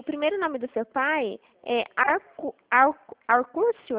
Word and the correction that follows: Acúrcio: assim que se pronuncia? – Acúrcio.